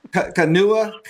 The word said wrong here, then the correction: Kahanu: Kai, linebacker, is in the current class. Would I have that right? Vince and Robbie, anybody know about? I'm not sure if I'm pronouncing his name right. K-A-H-A-N-U-K-I-A. A linebacker. – Are you talking about Kahanu? – Yes. There you go. – Yes. – Thank you for helping. – Oh, okay. Yeah, Kahanu Kia.